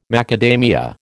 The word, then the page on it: macadamia: American English